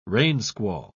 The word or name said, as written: rainsquall